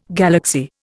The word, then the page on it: galaxy: Indian